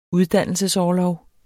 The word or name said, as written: uddannelsesorlov